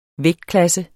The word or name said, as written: vægtklasse